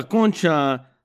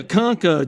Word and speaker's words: Concha: His mom, my tia, Tia Concha, not Tia Conca, GGP, okay? That's how you say it.